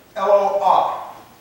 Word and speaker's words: Eloah: Okay, it's plural for the word Eloah. Aloha. Almost like Aloha, you know, a Hawaiian thing.